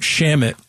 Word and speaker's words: Shamet: And every time he checks in, I'm reminded of the fact that Shamet rhymes with damn it.